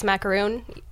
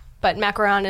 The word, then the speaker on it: macaron: Yeah, so I actually, up until a couple weeks ago, still pronounced it as macaroon for macaron, but macaron is the real way to say it.